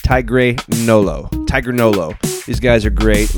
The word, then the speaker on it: TigreNolo: And for this edition of the playlist, we bring you an interview with our good friends TigreNolo.